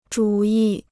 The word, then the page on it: 主义: zhǔ yì